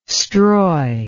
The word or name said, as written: stroy